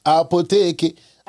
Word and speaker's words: Apotheke: Now, let's talk about Opitki. – Mm-hmm. – Did I say that right? – Apotheke. – Apotheke. – That's apotheke. It's German, actually.